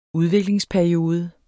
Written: udviklingsperiode

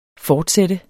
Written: fortsat

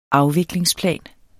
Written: afviklingsplan

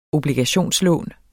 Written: obligationslån

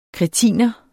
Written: kretiner

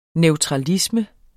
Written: neutralisme

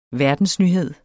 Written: verdensnyhed

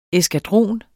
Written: eskadron

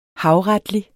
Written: havretlig

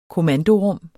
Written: kommandorum

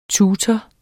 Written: tutor

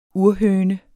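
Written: urhøner

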